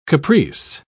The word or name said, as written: caprice